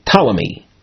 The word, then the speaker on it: Ptolemy: Talmai is often in English pronounced Ptolemy.